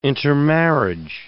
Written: intermarriage

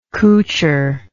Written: coucher

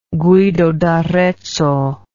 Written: Guido d'Arezzo

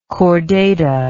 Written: chordata

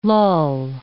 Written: lull

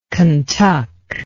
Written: Kentuck

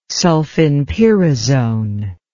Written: sulfinpyrazone

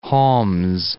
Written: Homs

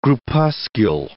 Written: groupuscule

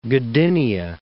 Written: Gdynia

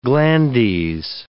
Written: glandes

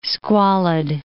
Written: squalid

